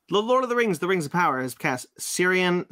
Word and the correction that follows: Ciarán Hinds: Ciarán Hinds. – Ciarán Hinds, thank you.